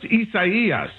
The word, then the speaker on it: Isaias: It is, and it's pronounced Isaias, if you're trying to follow along at home.